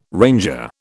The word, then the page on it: ranger: British English